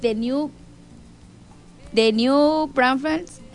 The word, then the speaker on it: New Braunfels: Dice por acá, un saludo para la raza de New Braunfels,